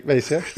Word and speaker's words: Vacier: Ja, jag skulle nog vilja säga att det uttalas Vacier. Det stavas i alla fall Vacier.